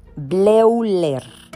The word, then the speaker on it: Bleuler: Bleuler. Se pronuncia Bleuler, Bleuler pero se escribe Bleuler, ahí para que lo googlen.